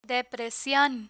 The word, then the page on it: depresion: Spanish